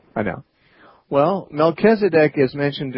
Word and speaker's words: Melchizedek: It's actually, Melchizedek.